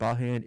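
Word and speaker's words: Bajan: He's the only Bahan, and Bahan is how you pronounce the people of Barbados.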